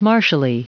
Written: martially

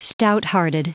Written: stouthearted

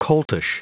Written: coltish

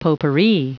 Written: potpourri

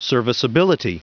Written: serviceability

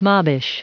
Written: mobbish

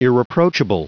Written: irreproachable

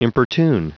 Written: importune

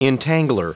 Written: entangler

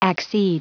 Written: accede

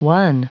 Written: won

